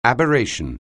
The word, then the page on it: aberration: anglais